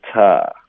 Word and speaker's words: to: To 要弱读成 ta.